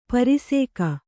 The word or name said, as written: ಪರಿಸೇಕ